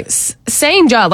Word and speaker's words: Cijntje: Sanja like sounds like, okay, cool, yeah. Whatever. That sounds normal. But it's spelled C-I-J-N-T-J-E.